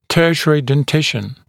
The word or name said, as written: tertiary dentition